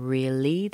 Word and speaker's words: really: And you also need to consider the R-E-A is pronounced ri, as in a short I, as in hit. R, R. The L-Y is li, a long E. So they're two different sounds. It's not really, it's really, really.